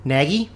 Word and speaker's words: Nagy: Nagy? – Can you spell it? – N-A-G-Y.